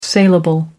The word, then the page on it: salable: American English